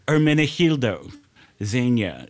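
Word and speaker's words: Ermenegildo Zegna: Hermenegildo Zegna. – What was it? – The name of the designer. Hermenegildo Zegna.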